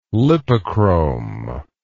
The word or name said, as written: lipochrome